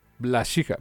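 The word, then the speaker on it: Wlaschiha: Es actor alemán, entonces es Glaschija, no tiene nada de difícil, dude.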